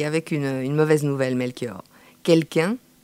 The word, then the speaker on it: KELK1: Orthographié, je vous le rappelle, K-E-L-K-1, prononcé quelqu'un.